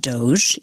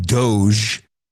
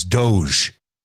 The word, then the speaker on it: Doge: The pronunciation of Doge is about the shitcoin known as Doge. Otherwise, it would be Doge or something. So the fact that it's Doge is completely connects it to that.